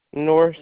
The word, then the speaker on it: North: E-R-I-C-A. – And last name? – North, N-O-R-T-H.